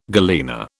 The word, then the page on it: galena: British English